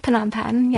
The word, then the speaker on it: Phnom Penh: Is it Phnom Penh? – Phnom Penh.